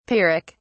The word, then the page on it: pyrrhic: British English